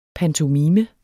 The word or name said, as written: pantomime